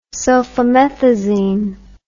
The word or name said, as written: sulfamethazine